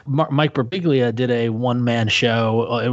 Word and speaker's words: Birbiglia: Birbiglia.